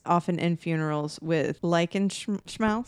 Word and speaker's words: Schmaus: S-C-H-M-A-U-S. Uh-huh. Schmaus. – Yeah. – Yeah. You really got to overdo the schmaus. – Like a schmaus. – Yeah. Like schmutzig.